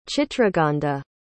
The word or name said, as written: chitragandha